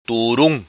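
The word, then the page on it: Turum: Macanese Patuá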